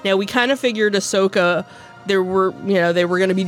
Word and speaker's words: Ahsoka: One is Rangers of the New Republic, and the other is Ahsoka. – Ahsoka. I don't know why. When I look at it, I can't. – It's pronounced just the way it's spelled. – Sure. – Ahsoka.